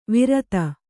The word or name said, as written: ವಿರಾಧ